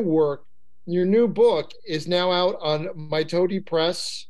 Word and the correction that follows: Mitote: I'm probably mispronouncing that too. – Uh, Mitote. – Mitote. – Press. Mitote. Yeah.